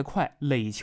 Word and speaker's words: 块垒: 这个块垒啊，一块的块，垒球的垒，你要是能准确读出来一个本地发音，块垒啊，操块垒，那 80% 大同人没跑了。